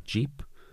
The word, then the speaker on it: JAEP: But it is published by, I don't know how to pronounce this, Jeep. Okay. J-A-E-P.